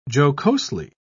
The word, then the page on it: jocosely: British English